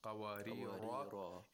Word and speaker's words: قوارير: If you connect this word to the next verse, there would be no tanween. قوارير قوارير. Okay, but if you stop, you have to pronounce it with alif al-mat. And the reason that this word is pronounced with alif al-mat is to so that the rhyme is the same as the other verses.